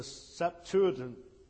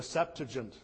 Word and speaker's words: Septuagint: It is known as the Septuagint, or the Septuagint. Some people have different pronunciations.